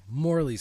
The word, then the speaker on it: Morly: M-O-R-L-Y? – Morley sounds right.